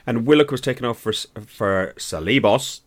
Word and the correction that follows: Ceballos: Ceballos, sorry.